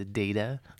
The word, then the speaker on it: data: But isn't the rule of the English language that if there's only one consonant between two vowels, the first vowel has a long vowel sound? So it's a data, not but, data.